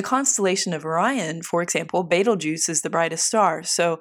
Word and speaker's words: Betelgeuse: Vega, for example, means swooping eagle, and Betelgeuse, pronounced by some Betelgeuse, means the right hand of the central one.